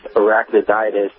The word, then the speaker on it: arachnoiditis: and ended up with arachnoiditis. I think I said that right. – Yep, you did.